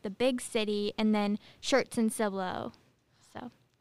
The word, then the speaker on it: Schertz: It's called Schertz.